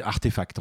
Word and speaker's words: RTFKT: Ah, en gros, on dit RT, Artefact, en fait, hein, mais c'est, ça s'écrit RTFKT.